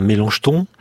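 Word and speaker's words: Melanchthon: Et le débat fait rage au XVIIe siècle avec un certain Mélenchon. – Oui. – J'ai bien prononcé? – Oui, vous l'avez bien prononcé.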